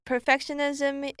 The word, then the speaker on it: perfectionism: Our video today talks about 8 signs of a dysfunctional family, perfectionism being one of them. So this is also our sentence of the day. When perfectionism is highly regarded and children have to bear their parents' unrealistic expectations, they may grow up with self-image and self-esteem issues, believing they'll never be good enough.